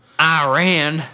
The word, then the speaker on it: Iran: I'm pretty sure Iran was in our group. That was a big deal because we got our butts kicked by them. – Iran.